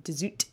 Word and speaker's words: de Zoet: And I haven't read The Thousand Autumns of Jacob de Zoet, as I gather it's pronounced, but everyone who has read it said to me that it's very good.